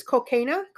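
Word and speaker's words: Kolkena: I hope I said that right. – I think it's Colquina.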